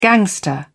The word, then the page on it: gangster: British English